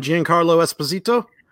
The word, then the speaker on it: Giancarlo Esposito: So the trailer has, and I hope I'm pronouncing his name right, Giancarlo Esposito. – Yes. – Yes.